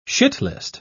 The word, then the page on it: shitlist: British English